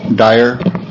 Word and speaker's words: Dyer: For the record, my name is Davis Dyer. Last name is spelled D-Y-E-R.